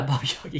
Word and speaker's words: Baba Yaga: So Baba Yaga. No? Did I say it right? Yeah, okay. – Yeah, Baba Yaga, yeah.